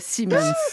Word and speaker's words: Siemens: C'est à l'allemande, Siemens.